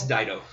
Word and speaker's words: Dido: Or how do you say that? Dido? – It's Dido.